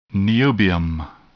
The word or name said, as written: niobium